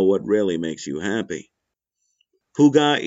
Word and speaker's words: hygge: Hygge, pronounced hygge.